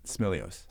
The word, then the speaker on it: Smilios: Smilios.